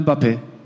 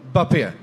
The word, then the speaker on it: Mbappé: Med sitt stumma M i början av allt. – Alltså, är det så? Kan vi få ett riktigt uttal på? – Kylian Mbappé. – Mbappé. – Ja, exakt så.